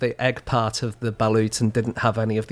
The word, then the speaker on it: balut: So this is balut. – Yep, so I had that balut. – Did you? Yep. Balut is how you say it.